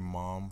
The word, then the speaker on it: MOMB: This is Medulla Oblongata, a.k.a. Mom for short. Yes, the B is silent.